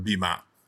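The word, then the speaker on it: bimah: I do know that today you'll hear them pronounce the platform that we name our podcast after, the Bima.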